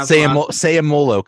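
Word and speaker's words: Seumalo: If you don't go with Patrick Peterson, you got to go with Isaac Sumalo. Am I saying all right, Sumalo? How does he say his name? – Sayamolo, kind of like Polamalu.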